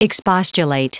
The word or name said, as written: expostulate